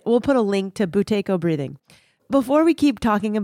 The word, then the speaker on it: Buteyko: Buteyko breathing? – Buteyko.